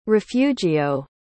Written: refugio

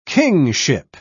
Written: kingship

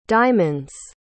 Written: diamonds

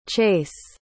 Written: Chace

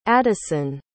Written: adyson

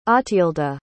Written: otthilda

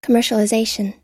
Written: commercialization

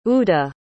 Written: udeh

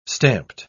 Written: stamped

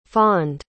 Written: fawned

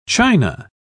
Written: china

